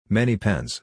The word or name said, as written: many pens